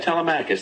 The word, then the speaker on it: Telemachus: pronounced it differently, but telemacious. – Is that T-E-L-E-M-A something? What would come after T-H-U-S. – Oh, yeah, Telemachus.